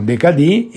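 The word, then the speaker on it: décadi: Il decadì è il 10, si scrive decadi, no? Decadi, in francese lo pronunciamo decadì.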